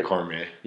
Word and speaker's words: Cormier: Like, good fighters like Cormier, or is that how you pronounce it? – Yeah, Cormier.